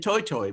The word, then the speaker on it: toetoe: where it's called the New Zealand Toi Toi, which is spelled T-O-E-T-O-E.